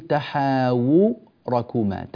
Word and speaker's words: تحاوركما: هذه الواو، الواو هنا في كلمة تحاوركما كما ترونها مظللة أمامكم، هذه الواو ينبغي العناية بنطقها مضمومة بشكل صحيح، فنقول تحاوركما تحاوركما تحاوركما، ولا نجعل هذه الكلمة بكسر الواو مثلا أو عدم بيان الأمر بوضوح.